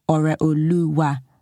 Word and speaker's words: Ore Oluwa: My full name is Ore Oluwa, and there's a dot under the O and a dot under the E to show that it is Ore and not Ore, and there's an accent on the U and the A to show that it goes Ore Oluwa, goes up and down.